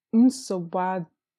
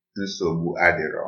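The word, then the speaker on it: Nsogbu adịghị: Yes, that is the proper way to say it, and that is the proper way to write it. But it is very often that you will hear Nsogbu Adiro. Mm-hmm. So, Nsogbu Adiro is the Anambra dialect,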